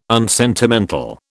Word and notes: unsentimental: British English

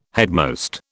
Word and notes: headmost: British English

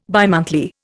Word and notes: bimonthly: Indian

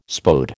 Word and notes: Spode: American English

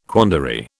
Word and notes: quandary: British English

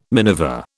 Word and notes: miniver: British English

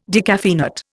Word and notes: decaffeinate: Indian

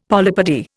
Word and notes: polypody: Indian

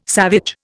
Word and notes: savage: Indian

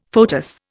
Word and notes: foetus: Indian

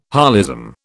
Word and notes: holism: British English

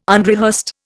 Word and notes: unrehearsed: Indian

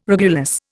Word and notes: regulus: Indian

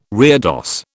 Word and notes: reredos: British English

